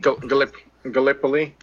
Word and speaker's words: Gallipoli: So this is Gallipoli. I don't know how to say it. – Gallipoli. – Gallipoli.